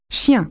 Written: dog

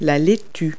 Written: lettuce

